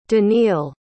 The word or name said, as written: daniil